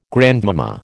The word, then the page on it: grandmama: American English